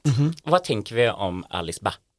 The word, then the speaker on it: Alice Bah: Jag tror man ska uttala det. Alice Bah, som ett ord.